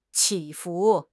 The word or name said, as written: 起伏